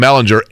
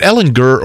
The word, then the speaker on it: Ehlinger: Ehlinger, right? Is it Ehlinger or Ehlinger? – Gurr. Hard G. – Are we sure on that? – We are. We clarified with Matt Taylor last Friday.